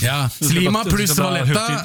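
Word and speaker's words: Sliema: Slima, staveste, okay, ja. Men det uttales Slima.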